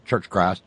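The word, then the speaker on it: Church of Christ: Church Christ, that's how you say it down south. Church Christ.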